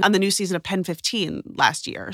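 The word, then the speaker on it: PEN15: Is that how you say the name of that show? It's not just pronounced penis? – No, it's called Pen 15.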